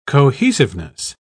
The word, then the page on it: cohesiveness: British English